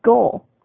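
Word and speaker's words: gaol: So I get up there, and it's actually the Cork County Goal, G-A-O-L. I'm like, holy crap, that is amazing. It's G-A-O-L, and the hard G, it actually sounds like jail.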